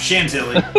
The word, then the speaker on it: Chantilly: And how do you pronounce it? Is it Chantilly, Virginia? Is that, I get that? How close was I? – Close enough. Chantilly. Chantilly. There we go. Chantilly, Virginia.